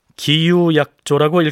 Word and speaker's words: 기유약조: 광해군 1년인 이 해가 바로 기운년이었기 때문에 역사 기록에서는 이 조약을 기유약조라고 읽었습니다.